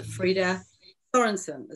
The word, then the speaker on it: Frida Sorensen: And her name is Frida Sorensen. Is that how you say it? Yeah. – Yes. Perfect.